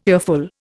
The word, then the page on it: earful: Indian